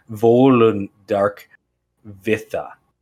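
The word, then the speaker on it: Völundarkviða: So King Olaf Geirstad, elf, the smith hero of Volundr, is identified as the ruler of elves in the poem Volundarkvion. No, I said that wrong. Volundarkvitha.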